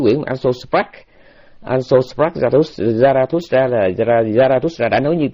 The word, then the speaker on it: Zarathustra: Tiếng Đức thì nó đọc là Zarathustra, còn tiếng Pháp nó là Zoroastre. Rồi còn bên tụi Đức nó đọc theo tiếng tiếng Ba Tư là Zarathustra, mục tiêu là Zarathustra.